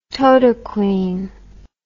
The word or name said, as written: totaquine